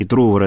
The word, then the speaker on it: Трувор: я не знаю, правильно ли делаю ударение? – Ну, Синеус и Трувор, наверное. – Да, да, Трувор, скорее всего.